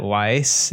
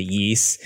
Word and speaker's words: Ys: You know, the first time I pronounced this series, I called it Weiss, and I know someone out there in the audience corrected me to Yeast.